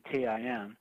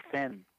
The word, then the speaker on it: Thin: But people in Dublin will still pronounce it tin, even though there's an H. And, I sort of jump out and say, "No, it's not tin, it's thin,"